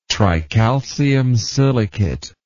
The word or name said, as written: tricalcium silicate